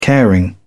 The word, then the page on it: caring: British English